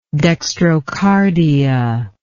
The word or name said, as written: dextrocardia